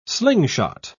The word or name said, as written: slingshot